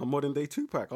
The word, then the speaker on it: Tupac: Let's say his name properly. Pac. Tupac. – Tupac. – Tupac. – Tupac. – Pac. – P-A-C. – It's closer to Pac.